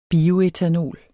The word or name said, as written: bioætanol